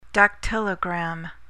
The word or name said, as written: dactylogram